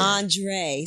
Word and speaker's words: Andra: Andre. So it's not pronounced, it's spelled Andra. – Yeah. – But it's pronounced Andre. Different.